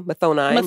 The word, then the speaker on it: Mathoni: Mathoni. – Maroni. Right. We're going to take some cues from the pronunciation of Moroni and apply words. – It to Mathoni.